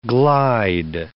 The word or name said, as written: glide